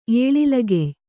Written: ಏಳಿಲಗೆಯ್

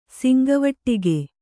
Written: ಶಂಖವಟಕ

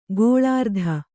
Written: ಗೋಲಾರ್ಧ

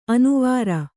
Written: ಅನುವರ

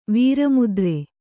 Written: ವೀರಮುದ್ರೆ

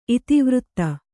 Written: ಇತಿವೃತ್ತ

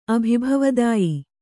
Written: ಅಭಿಭವದಾಯಿ

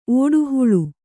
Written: ಓಡುಹುಳು

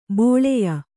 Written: ಬಾಲೇಯ

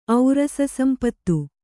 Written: ಔರಸಸಂಪತ್ತು

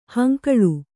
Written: ಹಂಗಳಿ

